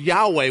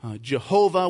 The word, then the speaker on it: Yahweh: The first use of the word Lord in this passage is the tetragrammaton, which is the word Yahweh, which is how we sometimes pronounce it, but it's YHWH. It's called Yahweh, other times called Jehovah, which is the Lord's covenantal name, the name that he revealed to Moses at the burning bush.